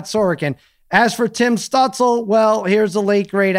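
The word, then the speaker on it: Stützle: The Stutzel. – Late, great Stutzel, he says. So there you go. That's apparently how you say it.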